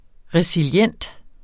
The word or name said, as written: resilient